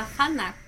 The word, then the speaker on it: Fanuc: Em quarto lugar, nós temos a FANAC, o código é 6954. Se escreve Fanuc, tá? Mas se lê Fanac.